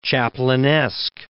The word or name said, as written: Chaplinesque